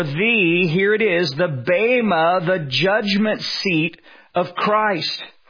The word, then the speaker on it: bema: It's spelled bema, B-E-M-A, but it's pronounced bema.